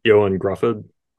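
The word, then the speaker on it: Ioan Gruffudd: Ion Gruffudd? – Oh yeah, you know? Johan Gruffudd.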